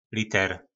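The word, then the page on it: Liter: nářečí Po našimu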